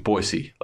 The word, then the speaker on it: Boise: But when they do have a chance to throw, Boise, as apparently we're supposed to be saying, Boise, like really pronounce the S, not like a Z.